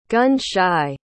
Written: gun-shy

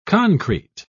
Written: concrete

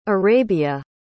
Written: Arabia

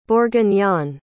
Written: bourguignon

